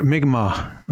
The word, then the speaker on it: Mi'kmaq: Mi'kmaq.